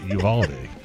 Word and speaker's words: Uvalde: Well, Uvalde. My close. – Gosh. – Close enough. – Buffalo. – Just say Texas. – Yeah, it is. Uvalde.